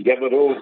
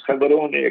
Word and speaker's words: Gaborone: In the Gaborone, what people say, Gaborone, is Gaborone, actually. They say the proper pronunciation.